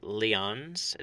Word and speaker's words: Lyons: In 2010, Roscoe finally gets himself a job in, I think it's pronounced Lyons. It's L-Y-O-N-S. So I'll say Lyons.